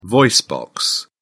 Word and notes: voice box: British English

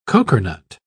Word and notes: cokernut: British English

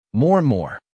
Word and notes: more and more: American English

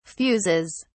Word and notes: fuses: British English

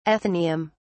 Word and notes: athenaeum: British English

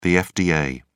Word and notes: fda: British English

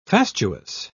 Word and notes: fastuous: British English

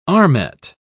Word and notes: armet: British English

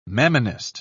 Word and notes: mammonist: British English

